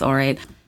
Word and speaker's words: Aurate: And I'm obsessed with Orate. It's spelled A-U-R-A-T-E, by the way, in case you weren't sure.